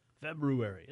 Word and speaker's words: February: And like this is the first day of February, the month that's hard for some people to pronounce because they can't get that R in there. February.